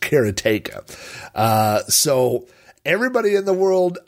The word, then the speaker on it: Karateka: So just to clarify before we get started, I will be saying Broderbund, and I will be calling this game Karateka, which is the correct pronunciation. I hate to tell the guy that wrote the game, but that's how you say it.